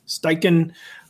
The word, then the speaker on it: Steichen: Steichen.